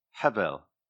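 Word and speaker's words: Hevel (הֶבֶל): So let's get back to the original pronunciation, Hevel.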